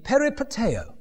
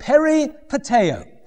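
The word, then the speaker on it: peripateo (περιπατέω): It's the word in Greek, peripateo, right? That's not how modern Greeks would pronounce it, but peripateo.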